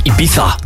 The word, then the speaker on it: Ibiza: Of Ibiza, zo moet je dat uitspreken.